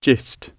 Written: gist

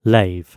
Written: lave

